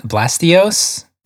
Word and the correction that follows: Blastoise: Did you just call Blastoise Blastios? – I wasn't sure.